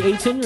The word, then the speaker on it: Ayton: DeAndre Ayton, or am I getting his name right? – Yeah, that's right.